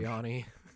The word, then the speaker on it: Sirianni: I'm going to butcher this last name, Sirianni, the Eagles head Sirianni. – Coach. – Sirianni. – Thank you.